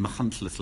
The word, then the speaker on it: Machynlleth: That's a fantastic Nigella Farage, which is an act I did in Machynlleth last year at the Comedy Festival.